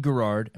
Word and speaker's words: Garard: Ricky Garard.